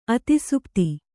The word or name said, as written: ಅತಿಸುಪ್ತಿ